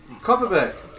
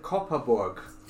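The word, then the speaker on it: Kopparberg: In the meantime, so, Copperberg. Yeah, that's probably how most people think it's pronounced, but you actually pronounce it Copperborg.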